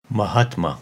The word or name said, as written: Mahatma